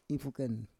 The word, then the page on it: ifuken: Roon